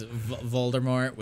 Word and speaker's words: Voldemort: The T is silent. It was like a French thing. – A French pronunciation. – And all I can see is Voldemort with his, like, douche dash.